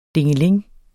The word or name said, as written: dingeling